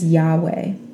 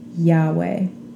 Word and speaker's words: Yahweh: There's no vowels in that word, so later we added our own vowels so that we could pronounce it. And we pronounce it as Yahweh. Yahweh.